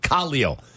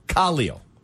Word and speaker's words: Khalil: John No H says, guys, it may have been unintentional, but the announcers actually were pronouncing Khalil Mack's name properly. Khalil. He was on Inside the Bears, the segment called Big Guys in a Benz. And Spice Adams, the former Bear, said, how do you pronounce your name? And he said, Khalil. Take that, Danny. – You don't remember that from last year? No, yeah. Ian Eagle wasn't pronouncing it accidentally incorrectly. He was pronouncing it correctly, but we've all been calling him Khalil Mack for so long, it's hard.